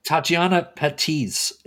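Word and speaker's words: Tatiana Patitz: I don't know if I'm pronouncing her name right. – Tatiana Patisse is her name.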